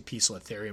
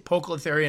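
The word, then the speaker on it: Poecilotheria: I was informed that that's how you say it. I know people say Pocolotharia, and obviously we call them Pokies. I do believe that's because of how it's mispronounced.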